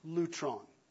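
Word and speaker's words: lutron (λύτρον): And when we do that, eventually we will come to a little Greek word pronounced lutron.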